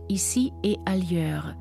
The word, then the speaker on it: Ici et ailleurs: 1976 erscheint Ici et ailleurs, hier und anderswo. Die Betonung liegt auf dem E, dem und.